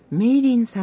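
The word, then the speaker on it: 美齢: それから、上京区黒門通り、中華料理店メイリンさんです。メイリンさんとは美しい年齢の齢と書いてメイリンさんと読むそうです。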